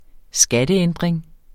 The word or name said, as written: skatteændring